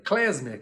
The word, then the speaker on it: klezmer: Klezmer, que se escreve K-L-E-Z-M-E-R, klezmer, K-L-E-M-Z-E-R, klezmer.